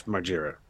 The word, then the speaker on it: Margera: Margera. – Oh, my God. – Margera. Jess Margera. Just say Jess Margera. – Jess Margera. – Oh. – Who's Jess Margera, you ask? The Jess Margera.